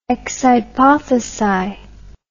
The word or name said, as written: ex hypothesi